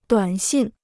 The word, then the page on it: 短信: duǎn xìn